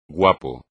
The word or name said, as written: guapo